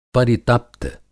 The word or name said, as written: परितप्त